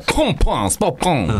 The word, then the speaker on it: パンパンスパパン: そうそう、あの表記的にはパンパンスパパンで、発音はポとパの間でポンポンスパポン、うん。